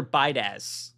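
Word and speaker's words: Biadasz: The Bears are hosting free agent center Tyler Bydash.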